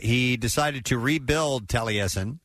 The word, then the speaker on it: Taliesin: By the way, somebody's texting in the name of the house is pronounced Taliesin.